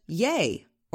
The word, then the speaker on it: Ye: This weekend, Kanye announced that his new name is just Ye, or Y-E, as in the last syllable in Kanye.